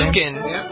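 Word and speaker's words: Liukin: Is it Lukin? Is that how you pronounce it? – Lukin.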